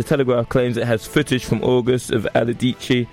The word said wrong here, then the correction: Allardyce: Are you calling Aladici? Him Aladici? – Allardyce. – Allardyce. – Yeah. – We're going to go with that.